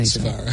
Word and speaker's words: Silvera: Local bands in town, and they are called Silvera. – Indeed. – Yeah? Is that how to say it right? – Yeah. – Because I said it before and you said it differently. – Silvera.